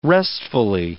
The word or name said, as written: restfully